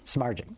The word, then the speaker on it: Samardzic: She said, no D, Samardzic, I think. – Samardzic.